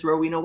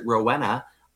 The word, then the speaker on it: Rowena: I mean, that's why my name is Rowena Way. I chose it. And when people pronounce it Rowena, I look at them, I'm like, no. It's Rowena.